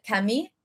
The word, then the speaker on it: Camille: Is how French people, like her name is spelled Camille, but French people pronounce it Cammy.